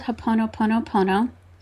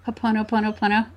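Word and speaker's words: Ho'oponopono (Hoʻoponopono): So that's called Ho'oponopono. And I love it. – I've misspelt it then. I've only got two ponos. – Ho'oponopono.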